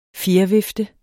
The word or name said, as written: fjervifte